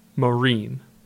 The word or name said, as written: Marine